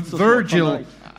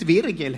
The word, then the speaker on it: Virgil: Uttala hans förnamn, är det, vi, det, var, det var Virgil? Rätt vanligt, det är ett Virgil.